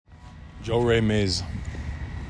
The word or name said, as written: Joe Ray Maez